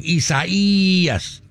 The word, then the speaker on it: Isaias: Make that second long E long. Isaias.